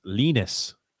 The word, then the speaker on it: Linus: Yeah, and just to clarify, Linus is pronounced Linus. – That's right. – I like Linus. Linus. Olmstrom. – No, not Linus. – Oh, man. – That's Linus from Charlie Brown.